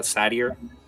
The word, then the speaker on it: satyr: Nickel is a satire. Uh, how the hell do you pronounce that? Uh, I want to say satire so bad. Uh, a satyr. – Satyr. – A satyr. Thank you.